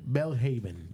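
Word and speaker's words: Belhaven: Quiero pronunciarlo bien. Es Bellhaven. – Bellhaven. – Ahí está. Bellhaven.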